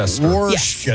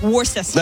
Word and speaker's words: Worcester: Is that the word that's spelled W-O-R-C? – Looks like Worcester. – Worcester. – Yes, yeah. Yes. Worcester. – Like okay. The sauce you put on your steak.